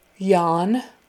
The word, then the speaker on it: yawn: Yawn, Y-A-W-N, like yawn. That A-W in the middle, you pronounce it like a short O. Ah, ah. So, ah, yawn, yawn. And forget that there's a W there. Don't try to pronounce the W at all, or it will sound funny. Just yawn, yawn, as if it were Y-O-N, yawn.